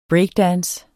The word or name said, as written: breakdance